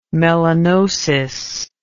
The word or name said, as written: melanosis